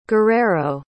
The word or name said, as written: guerrero